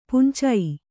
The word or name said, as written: ಪುಂಚೈ